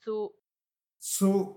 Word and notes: tsu: Macuiltianguis

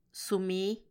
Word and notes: sumii: Macuiltianguis

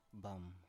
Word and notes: bam: Biak

